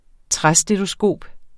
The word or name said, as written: træstetoskop